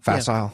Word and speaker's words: facile: And I think that it's just a facile... Is am I pronouncing that word right? Facile? – Mm-hmm. – Facile? Yeah. Facile. Mm-hmm.